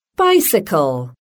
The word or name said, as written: bicycle